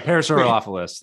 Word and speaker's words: Parasaurolophus: Parasaurolophus, I guess. – Yeah, Parasaurolophus. That's probably right.